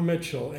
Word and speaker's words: Mitchell: Bob Mitchell, M-I-T-C-H-E-L-L.